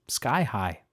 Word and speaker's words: Ski High: The selection by Claude Ivoire is called Ski High, or maybe they mean for it to be pronounced Sky High. I'm not sure. It's S-K-I-H-I-G-H. And I think you'll hear there's some little ski-sounding effects. So I'm going to go with Ski High.